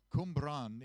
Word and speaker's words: Cwmbran: And there's a church at a place on the border of Wales called Cwmbran. And yeah, that's spelled C-W-M. So figure that one out. Cwmbran in Wales.